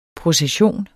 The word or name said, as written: procession